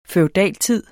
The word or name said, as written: feudaltid